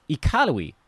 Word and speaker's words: Iqaluit: Someone taught me that the capital up there is pronounced Iqaluit.